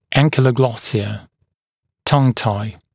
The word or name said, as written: ankyloglossia tongue-tie